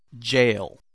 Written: jail

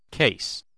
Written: case

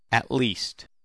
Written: at least

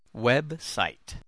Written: website

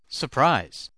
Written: surprise

